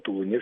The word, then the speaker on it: Тулуне: Тулун, Тулуне, Москва, Москве. Ну, что такого-то. И у нас много так городов. Беглое ударение. – Да, беглое ударение, но в случае с Тулуном все-таки, Ну, мне приходилось жить в Иркутской области, и там на самом деле Тулуне всегда было.